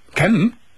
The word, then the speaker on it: Cem: Er oder sie heißt, wird geschrieben, C-E-M. Cem? – Ja, Cem.